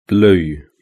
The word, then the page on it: blue: British English